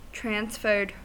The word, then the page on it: transferred: American English